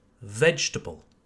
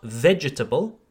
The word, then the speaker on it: vegetable: So it's not vegetable. You need to be lazier, lazier, plus feignant. Okay, forget the second E, vegetable.